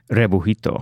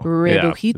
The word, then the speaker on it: Rebujito: You can't even pronounce that. – Yeah, I did. I just did. – Out of breath, did she? – Rebujito. – Rebujito. Yeah, that's how you pronounce it.